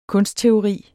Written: kunstteori

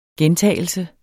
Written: gentagelse

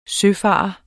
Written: søfarer

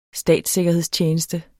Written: statssikkerhedstjeneste

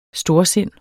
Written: storsind

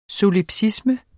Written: solipsisme

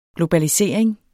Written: globalisering

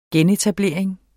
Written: genetablering

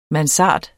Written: mansard

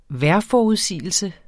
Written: vejrforudsigelse

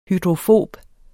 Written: hydrofob

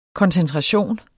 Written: koncentration

